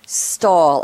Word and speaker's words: Stahel: Stahel. I'll pronounce his name that way, but I'm going to spell it for you. S-T-A-H-E-L.